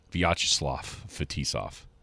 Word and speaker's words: Vyacheslav Fetisov: Vyacheslav Fetisov. That's how you say it.